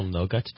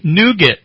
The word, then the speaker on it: nougat: Nougat.